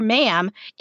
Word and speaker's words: ma'am: And after that, you can call her ma'am. It rhymes with ham.